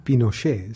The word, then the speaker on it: Pinochet: Well, I think Pinochet is the right pronunciation.